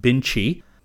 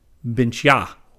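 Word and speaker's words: Bintje: Bart told me that the Dutch potatoes I was talking about in the August 2011 Science News are not pronounced Binchi, they are pronounced Bintja, A as in again.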